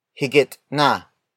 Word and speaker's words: higit na: If you prefer a native Tagalog version of mas, you can say higit na, higit na, higit na. Higit na is spelled as H-I-G-I-T space N-A.